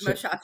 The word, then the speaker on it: Moshasha: Close, Moshasha.